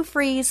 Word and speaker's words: Fries: And it's Sue Freeze, spelled like fries, one word, .com.